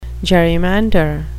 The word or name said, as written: gerrymander